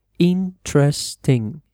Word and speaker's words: interesting: Then we have interesting, interesante, be careful with the stress, cuidado con la pronunciación. Interesting, interesting. You don't say interesting or something like that. You say interesting.